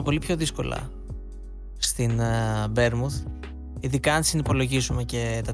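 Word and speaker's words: Bournemouth: Μ' αρέσει πολύ αυτό το ναι, Μπέρμουθ. Έτσι, έχει ωραία προφορά.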